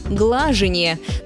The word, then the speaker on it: глажение: Одно из них – глажение. Нормативное ударение падает на первый слог, как в слове «гладить» или разговорном «глажка».